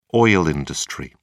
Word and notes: oil industry: British English

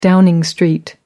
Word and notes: Downing Street: British English

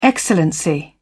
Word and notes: excellency: British English

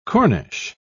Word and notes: corniche: British English

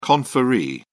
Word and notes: conferee: British English

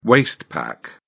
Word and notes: waist pack: British English

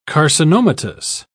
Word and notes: carcinomatous: British English

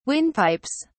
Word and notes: windpipes: British English

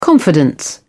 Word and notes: confidence: British English

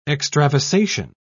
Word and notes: extravasation: British English

